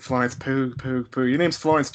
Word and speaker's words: Pugh: No, I keep saying it wrong. I think it's Florence Pugh. Pugh.